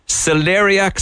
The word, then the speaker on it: celeriac: I had, am I pronouncing this right, celeriac soup? – Oh, yes, yeah.